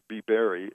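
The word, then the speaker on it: bibere: The word beer comes from Latin and pronounced biberi, and it means to drink.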